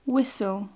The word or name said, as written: whistle